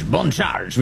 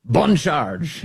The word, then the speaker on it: Boncharge: Boncharge, we say with a slight French accent, so I can pronounce that B-O-N perfectly. Boncharge.